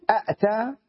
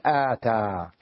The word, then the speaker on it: آتى: هكذا إذا زدت همزة أنا على أتى، بدل أن أقول أأتى أقلب الثانية إلى مد إلى ألف حتى تصبح سهلة النطق فأقول آتى.